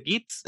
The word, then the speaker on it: Git: Y no sé por qué es un hub de Git Entonces, probablemente tú puedes explicar que mm, diantres es un get? Eh, un get. Mm, esa, pronunciación, como la está haciendo, me cuesta trabajo porque yo siempre leo git.